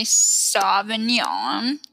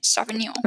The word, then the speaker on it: Sauvignon: It's called the Jack Cabernet Sauvignon. That was a terrible pronunciation. Sauvignon.